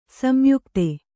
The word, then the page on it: ಸಂಯುಕ್ತ: samyukta